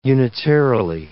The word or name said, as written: unitarily